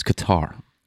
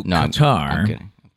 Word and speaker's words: Qatar: By the way, Justin said, I think it's Qatar. That's what I think that's how he pronounced it. – All right, Qatar couldn't work. Yeah. Q-A-T-A-R. I've heard Qatar. – I've always heard it as Qatar. – You've always heard Qatar? – Yeah.